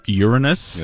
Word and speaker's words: Uranus: Andrew, I believe it's pronounced Uranus.